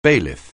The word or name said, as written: bailiff